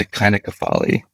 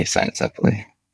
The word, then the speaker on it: cynocephaly: If you're using the restored classical Latin, it would be the cynocephaly. But we just say cynocephaly.